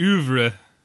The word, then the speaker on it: oeuvre: Yeah, it runs throughout his film kind of au revoir. Is that, did I say that right? – I think it's oeuvre. – Oeuvre. Okay.